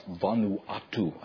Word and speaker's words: Vanuatu: Payton went to the New Hebrides, now pronounced Vanuatu. I think I'm putting the right emphasis on the right syllable.